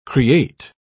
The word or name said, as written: create